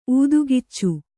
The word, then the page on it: ಊದುಗಿಚ್ಚು: ūdugiccu